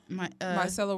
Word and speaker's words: micellar: They have this detoxifying charcoal micellar. Is that how you pronounce it? – Yeah. Micellar water.